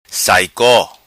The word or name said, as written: Saico